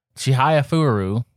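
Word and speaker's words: Chihayafuru: And Chihaya Furu, and I am pronouncing that right.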